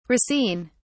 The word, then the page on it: racine: British English